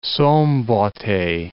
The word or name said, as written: Szombathely